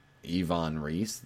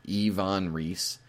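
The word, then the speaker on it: Iwan Ries: Here in Chicago, we have the oldest still running, Yvonne Reese. That's right, it's E-E-V-A-H-N. That's how you pronounce it. Yvonne Reese.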